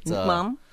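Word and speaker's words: nước mắm: Nukmam. – Oh, good job. That's a pretty good pronunciation with some decent tone there.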